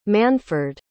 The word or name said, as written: manford